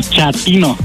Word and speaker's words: chatino: Chapino. – ¿Chapino? – No, chatino. – Es como chato, ¿verdad? Chatino. – Sí, asunero como chato, chatino.